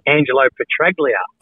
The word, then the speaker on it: Angelo Petraglia: Do you know Ange Pretalia? Yeah. – It's actually Angelo Petraglia. – Oh, is it a hard G?